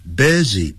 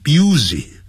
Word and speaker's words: busy: However，不过 occasionally or your friends may be busy，你的朋友们呢可能会很忙，busy 不要念成 busy 啊，busy。